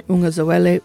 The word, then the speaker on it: ungizwile: The first is the word Ungazawele, which is spelled U-N-G-I-Z-W-I-L-E, which means you heard me. The next is the word Puma, which is spelled P-H-U-M-A, and it means get out.